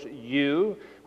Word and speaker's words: eu: It is the Greek EU, pronounced, of course, you, which means that the word it is attached to is to be understood as good.